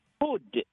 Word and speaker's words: food: அதாவது அதே சமயத்தில் ஃபுட் அப்படிங்கிற உணவு இருக்குது பார்த்தீங்களா, அதை கொஞ்சம் நீளமா ஃபுட் அப்படின்னு உச்சரிக்கணும். ஆனால் பாதம், ஃபுட் பாதம் இருக்கு பார்த்தீங்களா, அதுக்கு ஃபுட் அப்படின்னு சுருக்கமாக தான் சொல்லணும்.